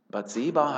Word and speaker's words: Bathseba: und es ist interessant, die Betonung, Bathseba heißt es hier, die, äh, die Witwe von Uriah war.